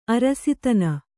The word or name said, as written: ಅರಸುತನ